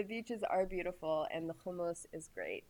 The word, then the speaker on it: hummus: Hummus. Say it like an Israeli. Yeah. – That's right. – Say it, Israeli. – Say it right. Say it right. Say ha. You got it. You got to get it in the back of your throat.